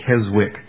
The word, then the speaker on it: Keswick: It's spelled K-E-S-W-I-C-K. It looks like Keswick, but I think it's pronounced Keswick.